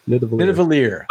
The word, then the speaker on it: Nidavellir: It's all part of Nidavellir. Am I saying it right? Nidavellir? – Nidavellir.